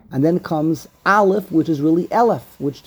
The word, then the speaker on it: Aleph: The next letter is the first letter, which is Aleph, which is pronounced Aleph.